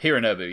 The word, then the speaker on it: Hironobu: Is it Hironobu? – Hironobu, yeah. – Sakaguchi, yeah.